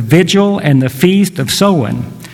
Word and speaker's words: Samhain: It's spelled S-A-M-H-A-I-N. You wouldn't think that it would be Samhain when it's spelled that way, but it is.